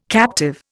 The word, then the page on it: captive: Indian